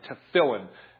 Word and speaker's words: tefillin: the Jews will refer to it nowadays as the tefillin, T-E-F-I-L-L-I-N, T-E-F-T-E-F-I-L-L-I-N, tefillin, and they'll put the emphasis on the middle syllable there, tefillin.